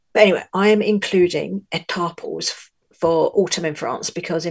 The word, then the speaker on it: Étaples: So I'm going to start with Étaples, which is spelled E-T-A-P-L-E-S, and lots of Brits pronounce it Étaples, but it's not, it's Étaples, which is close to where I live in the north of France.